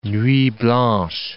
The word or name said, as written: nuit blanche